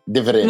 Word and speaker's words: Diferente: Did I say it right that time? Diferente. – Muy bien.